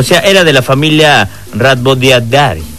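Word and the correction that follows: Rhabdoviridae: Venga, ¿cómo se pronuncia? – ¿Rabo qué? – Rabdoviridae.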